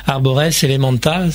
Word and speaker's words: Arbores Elementa: La BO des légendaires est disponible depuis le 23 janvier, avec notamment la chanson des légendaires et aussi Arbores Elementa. C'est comme ça que ça se dit, ça se prononce, oui.